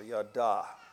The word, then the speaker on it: yada: Strong's 3034, yada. Now there is another word that to our eyes ought to have the same pronunciation. It's a Y-A-D-A without an H on the end. It's actually yada.